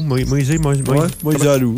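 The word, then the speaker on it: Moisés Alou: Et comment tu prononces, juste en parenthèse, on va fermer les micros en rond, comment tu prononces Moïse Alou? – Moïse Alou. – Moïse Alou. – Ouais.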